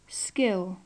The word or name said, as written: skill